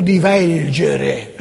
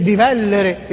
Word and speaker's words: divellere: divelgere. Divelgere, casco, divelgere, un giornalista della RAI da anni, allora divelgere, ma come divelgere, eh? Si scrive, si dice divellere,